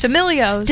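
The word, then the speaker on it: D'Amelio: Because the how do you say it? D'Alamos? D'Amelios? – D'Amelios. – D'Amelios, yes. The D'Amelios.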